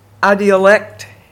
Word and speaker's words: idiolect: And Becky, do you know the term, I think it's called, pronounced idiolect, I-D-I-O-L-E-C-T, idiolect.